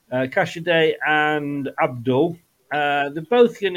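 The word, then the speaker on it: Casadei: But Kasidri and Fatawa. – Cassidy. I will teach you to say his name right. It's Cassidy.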